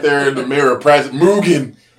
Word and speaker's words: Mugen: We signed a distribution deal with Mugen.